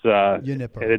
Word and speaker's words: Junipero: Is it Junipero Serra? – Yeah, Junipero. It's Junipero. – They don't usually use they just call it Serra. – Okay. – And there's two Serra. There's two Junipero Serras in California, one in LA and one in San Francisco.